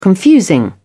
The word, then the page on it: confusing: British English